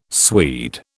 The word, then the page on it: Swede: British English